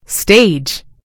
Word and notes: stage: British English

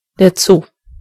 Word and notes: Zoo: German

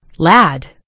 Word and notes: lad: American English

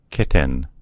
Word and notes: kitten: American English

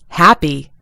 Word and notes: happy: American English

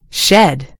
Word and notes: shed: American English

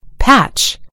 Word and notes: patch: British English

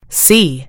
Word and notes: see: British English

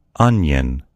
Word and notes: onion: American English

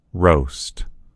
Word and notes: roast: American English

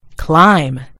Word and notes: climb: American English

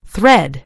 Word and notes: thread: British English

